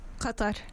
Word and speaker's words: Qatar: cut that.